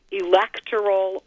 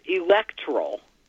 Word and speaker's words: electoral: Electoral, you could do either way. I mean, it's acceptable to do electoral or electoral, but technically the preferred is electoral with the pronunciation on the second.